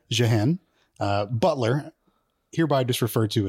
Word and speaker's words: Jeanne: So I committed a sin and went to a thinking machine and said, how the fuck do I pronounce this? And YouTube spit back out Jeanne.